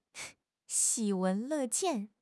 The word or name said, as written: 喜闻乐见